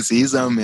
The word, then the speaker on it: Sesam: Äh, ich weiß nicht, ob ich es richtig ausgesprochen habe, aber ich glaube, ihr meint auch Sesam. Äh, warum Sesam? Tatsächlich meinen wir Sesam, ja.